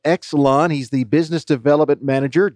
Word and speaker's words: YXLON: Obviously, the first question, Exelon, which is Y-X-L-O-N. It's how it's spelled, but it's pronounced Exelon.